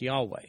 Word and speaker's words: Yahweh: or sometimes pronounced Yahweh.